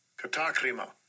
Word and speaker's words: katakrima: I'm not saying his way is wrong, but just remember, it's actually pronounced this way. – Strong's G, 2631, katakrima. Katakrima. – Katakrima. He's saying it in a different way. It's perfect. It's perfectly okay. Just a different way of pronouncing it.